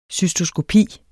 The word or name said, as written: cystoskopi